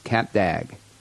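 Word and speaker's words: Cap d'Agde: It's pronounced Cap d'Agg, spelled weird, CAPD'AGDE, but it's pronounced Cap d'Agg.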